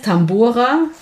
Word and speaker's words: Tambora: Ja, der heißt Tambora.